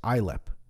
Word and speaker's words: Islip: So I thought that perhaps American pulling out of three cities, including Islip. Is that right? Or Islip? It's Islip.